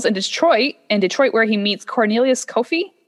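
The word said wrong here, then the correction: Coffey: I think his is pronounced Coffee mm-hmm. Kofi. – No, no, Coffee.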